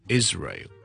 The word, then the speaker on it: Israel: 第一件事咧，以色列呢個國家咧，我哋咧個發音係 Israel。有好多件事要留意嘅，就係第一件事咧，嗰、那個 is 咧，雖然我串法係 s 咧，我哋嘅讀音係讀 z 嘅，所以係 Israel。 OK，第二件事要留意嘅咧，就係咧個 ray 完之後咧，Israel 你當係 ray 啦，ray 咁樣，Israel 之後我條脷要上翻去個 l 音，Israel。